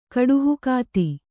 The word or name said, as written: ಕಡುಹುಕಾತಿ